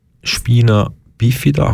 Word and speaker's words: Spina bifida: Das nennt sich Spina bifida. Habe ich das richtig ausgesprochen? Ja.